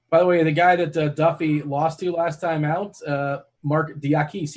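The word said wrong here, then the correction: Diakiese: he's also, I don't know if I pronounced that correctly. – Yeah, Diakese. – Oh, Diakese. Oh, I was way off.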